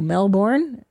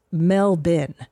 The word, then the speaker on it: Melbourne: And I go, Melbourne? She goes, no, it's pronounced Melbin.